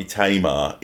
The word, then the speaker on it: Tamar: The only reason I thought it might be Tamar is that Prince once had a prodigy whose name was spelt T-A-M-A-R and she was Tamar. But I think that might be an American. That sounds like an American pronunciation.